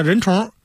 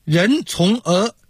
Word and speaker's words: 人虫: 比方说我写的小说叫人虫，南方人会念成人从儿啊，这好像是人虫的儿子，这挺别扭的。